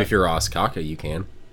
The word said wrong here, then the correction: Ostkaka: Actually, pronounced Ostkaka.